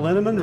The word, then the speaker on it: Linneman: The equally mysterious Tracy Z, Aviva Lael, Alicia Lemus, Rebecca Hughes, Val Linneman, Rhymes with Cinnamon,